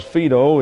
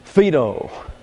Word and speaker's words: Phaedo: I'll put Phaedo up here because Phaedo is not pronounced the way it is spelled. Maybe the French had something to do with it, I don't know. Phaedo.